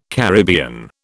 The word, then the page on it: Caribbean: British English